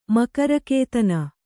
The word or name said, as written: ಮಕರಕೇತನ